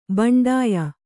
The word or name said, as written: ಬಂಡಾಯ